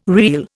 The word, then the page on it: reel: Indian